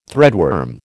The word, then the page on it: threadworm: American English